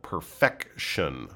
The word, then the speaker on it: perfection: We often might use the word perfect, but talking about perfect as a state perfect as a noun, perfection. Okay, and it's got that hard K sound in the middle, perfection. Okay, a little bit different from perfect right?